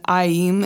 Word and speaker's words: Aim: So Aim, which is spelled A-I-M, but it's pronounced Aim, so it's often spelled A-I-E-E-M, just to make it more clear.